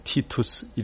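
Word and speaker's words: Titus: 티투스 이렇게 발음을 하는데요.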